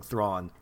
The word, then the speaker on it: Thrawn: Uh, but yeah, it's definitely a lot of uh, just absolute slavishly blowing Thrawn, which rhymes with Zon.